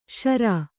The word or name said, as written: ಶಾರ